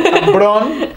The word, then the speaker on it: Ambrón: Y también de Spotify, en la cual pues nosotros subimos todos los podcasts, nos pueden encontrar como Ambron y pues con acento en la O. – Ambrón Sí, bien, por Dios.